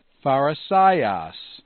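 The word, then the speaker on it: pharisaios (Φαρισαῖος): So, for example, it's pharisaios, where the accent is on the penult.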